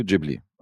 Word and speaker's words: Ghibli: Nee, eigentlich heißt es Studio Ghibli, aber wir sind, glaube ich, in der Minderheit, die das so aussprechen. – Mhm. – Die meisten sagen, glaube ich, Ghibli.